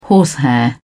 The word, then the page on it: horsehair: British English